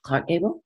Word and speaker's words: Gable: So, and Carole Lombard, I think, was special because she had that real love affair with Clark Gable.